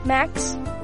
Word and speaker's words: Max: Max. – Max, can you spell that? – M-A-X.